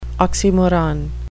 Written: oxymoron

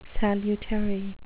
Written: salutary

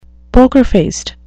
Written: poker-faced

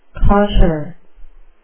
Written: cosher

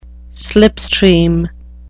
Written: slipstream

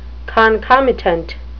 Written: concomitant

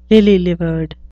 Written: lily-livered

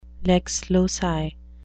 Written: lex loci

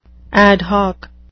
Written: ad hoc

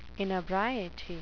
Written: inebriety

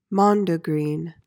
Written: mondegreen

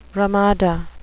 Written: ramada

